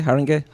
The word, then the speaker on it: Haringey: Ended up at Haringey Snooker Club. – Yes. – Is it pronounced right? Haringey? Haringey? – Haringey. – Haringey. I say Haringey.